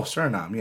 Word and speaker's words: Suriname: Suriname? – Oh, Suriname, yeah.